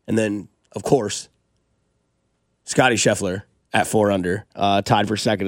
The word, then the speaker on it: Scheffler: I like Adam Scheffley, too. – Yeah. – Which one did I butcher there? – Scheffler? – Scheffler.